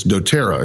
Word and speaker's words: doTERRA: Well, you know, the amazing thing, I bumped into, it's doTERRA is how it's pronounced, isn't it? – That's correct.